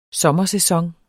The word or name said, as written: sommersæson